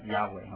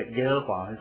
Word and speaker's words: 亚伟: 这个希伯来人以色列人是很尊敬他们的神，所以其实这个字是。不知道怎么发音的哈，就是我们后面的人把它发成亚伟哈，所以我们和本圣经把它翻成这个耶和华，照着德文的这个发音哈，自己加了一些母音把它翻出来哈。